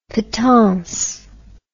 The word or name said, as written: patonce